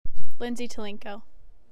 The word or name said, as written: Lindsay Tulenko